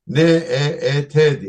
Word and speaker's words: NEET: N E E T diye, NEET diye okunuyor not in employment, education or training diye tabir ediliyor İngilizce.